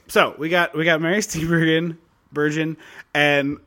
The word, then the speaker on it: Steenburgen: They say a hard G, so Steenburgen.